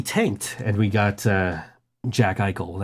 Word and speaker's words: Eichel: Eichel is how you say it? – Jack Eichel, yes. – It's a word with consonants that don't look like they should be there. – Yes, Jack Eichel.